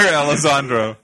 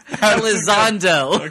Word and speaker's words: Elizondo: Just because I can't pronounce Hector Elizondo. – It's not Elizondo. There's no R in his name. Elizondo.